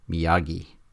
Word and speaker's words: Miyagi: It's Macchio and it's Miyagi. So anybody that's watched the movie several times, you know that you know that's the way that those are pronounced.